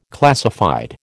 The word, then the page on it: classified: American English